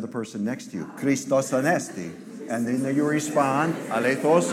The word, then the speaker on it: Christos Anesti: Now say it with a Greek accent. Christos Anesti. Tell us, Melina. Christos Anesti. See, she said it right.